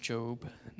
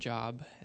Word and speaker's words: Job: Yes, that's pronounced Job, not Job, as I've heard before.